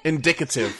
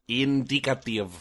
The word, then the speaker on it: indicative: That's so indicative. That's not how you pronounce that word. – Indicative. – Thank you. – The word was fine, you just dropped a syllable. Indicative.